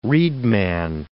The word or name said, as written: reedman